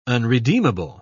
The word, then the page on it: unredeemable: British English